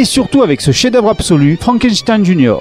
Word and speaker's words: Frankenstein: Mon nom, monsieur, se prononce Frankenstein.